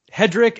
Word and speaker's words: Hedrich: Yeah, it's Hedrich. – Right? So it's Hedrich, and it's C-H, but it's pronounced like it's C-K.